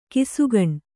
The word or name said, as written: ಘಸಕ್ಕನೆ